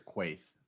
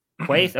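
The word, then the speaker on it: Kuithe: I'm going Brant – is it – how do you say his last name? – I'm pretty sure it's Quaith. – Quaith? Okay.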